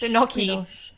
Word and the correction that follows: Binoche: We're going to have some fun with my pronunciations of names today. Everyone's really aware that I am not good at this. – Binoche.